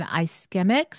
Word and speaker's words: ischemic: He specializes in ischemic strokes. Am I saying that right? Ischemic. – Yes, it's stroke, ischemic, and hemorrhagic, all kinds of stroke and vascular pathologies.